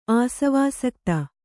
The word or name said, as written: ಆಸವಾಸಕ್ತ